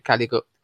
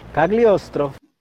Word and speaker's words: Cagliostro: Em 79, Lupin III, O Castelo de Caligo Cagliostro. Meu Deus, por que é nome em, italiano é tanto pra pronunciar? Cagliostro.